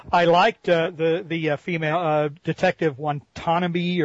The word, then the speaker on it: Watanabe: It's Watanabe.